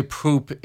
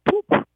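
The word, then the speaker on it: poep: Now, how do you say poop in Dutch? Go slow, go slow. – It is poop. – Oh, well, that's easy. – It just spells different. It's P-O-E-P. Poop.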